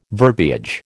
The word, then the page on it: verbiage: American English